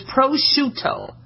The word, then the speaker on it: prosciutto: Okay, like prosciutto. Okay? A lot of times you guys are calling it prosciutto, all right? It's an Italian dry-cured ham, okay? Think of shoe and toe, so it's prosciutto, all right?